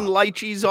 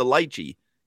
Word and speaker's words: lychee: It's L-Y-C-H-E-E. So they are determining that chi is spelled C-H-E-E at the end. The E sound comes from the E-E. So earlier in the word, to have a Y, I would assume that that's a different pronunciation because if they wanted lychee, you would spell it L-E-E-C-H-E-E, but they spell it L-Y-C-H-E-E. So that's lychee in my book.